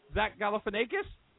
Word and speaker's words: Zach Galifianakis: Zach Galifianakis? – And you said his name properly.